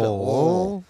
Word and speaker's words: Louisville: You got to say it like you got a mouthful spit. Louisville.